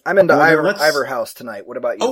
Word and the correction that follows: Inver House: Or Inver House.